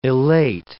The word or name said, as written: elate